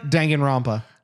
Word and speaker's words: Danganronpa: Danganronpa.